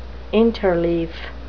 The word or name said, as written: interleaf